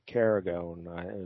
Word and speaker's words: Caragonne: Yeah, all right, I don't know if I'm pronouncing his name right. It's spelled C-A-R-A-G-O-N-N-E, Carragone is what we're going with.